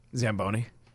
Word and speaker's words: Zamboni: Zamboni.